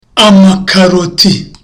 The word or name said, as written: amakaroti